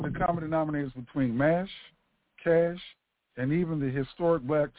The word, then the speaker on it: CSH: The successor to the Mobile Army Surgical Units, also known as MASH, is the Combat Support Hospitals, CSH, also known as CASH. Now let me read about that. Combat Support Hospital. Combat Support Hospital, CHS, pronounced CASH, is a type of modern U.S.